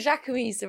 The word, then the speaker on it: Jacquemus: I think the Jacquemus are real.